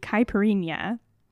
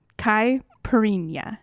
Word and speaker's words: Caipirinha: But today we're going to be making the Caipirinha, and that is a correct pronunciation. Last week was wrong. I checked it, and this is now correct.